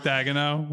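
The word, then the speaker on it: Daigneault: This is drumroll, please, on the pronunciation since it came up earlier. Mark Dagano with the Oklahoma City Thunder. – I think that's right. I think you got it.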